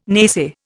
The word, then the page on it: naysay: Indian